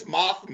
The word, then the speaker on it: Mothma: you butchered her name. – huh? Mothra stuck in your head. It's Mothma.